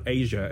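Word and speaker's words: Aja: By the way, that's not Asia as in A-S-I-A. That's A-J-A.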